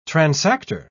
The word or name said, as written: transactor